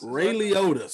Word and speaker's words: Ray Liotta: You see, how you pronounce my man? Ray Liotta. That's pronounced Ray Liotta, sir.